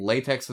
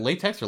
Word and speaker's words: LaTeX: Is it LaTeX or LaTeX? – Yeah, people say LaTeX. I think it should just be LaTeX if it's spelled that way, but what do I know? – Because it's an X, right?